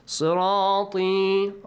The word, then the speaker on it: صراطي: صراطي. را has a heavy sound. طي. علي ناصر, you pronounce it this way. وأن هذا صراطي مستقيما. طي doesn't have a long sound. It has two counts.